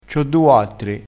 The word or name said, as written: quattri